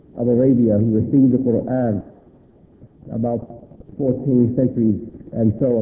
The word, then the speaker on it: Quran: the book that is called the Quran or the Quran by Western people's pronunciation, is Quran.